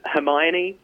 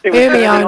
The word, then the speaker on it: Hermione: Like everyone who doesn't live in the UK, they mispronounced Hermione. – Oh yes, Hermione, was it?